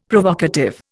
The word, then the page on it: Provocative: Indian